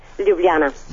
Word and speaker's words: Ljubljana: Ljubljana. – Ljubljana, okay. Yeah. I knew that. – Yeah. – The J is silent.